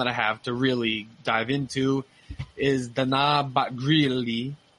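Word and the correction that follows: Batgerel: or Batgril, let me say that right. Dana Batgril versus Kevin Natividad.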